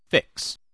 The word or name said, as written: fix